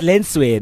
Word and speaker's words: Lentswe: When I was young, when I was in primary school, I used to tell people that the T is a silent T, like in French. No, it's LENTSWE.